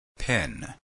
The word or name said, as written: pin